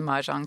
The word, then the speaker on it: mahjong: She's in the Mahjong circle.